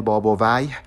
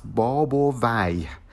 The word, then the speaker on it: بابویه: باب ویه که خب خیلی به غلط اسمش رو تلفظ میکنن بابویه یا بابویه ولی درستش هست باب و وی